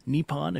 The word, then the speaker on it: Nippon: This all comes after Japan's Nippon Steel, or Nippon if you prefer the Japanese pronunciation, after it finally succeeded in acquiring U.S.